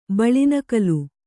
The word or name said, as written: ಬಳಿನಕಲು